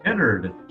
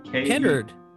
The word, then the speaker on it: Kennard: Hey, Cooper, how do you say your last name? – Kennard. Kennard. A-R-D, which is really important because our child's going to have to be pronounced it correctly at some point.